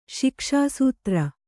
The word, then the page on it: ಶಿಕ್ಷಾಸೂತ್ರ: Kannada